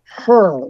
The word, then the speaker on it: Hur: Now, about the appointment of a special counsel to carry out the investigation of Biden's stash of classified documents, his name is Robert Hur, like spelled like Ben Hur, H-U-R.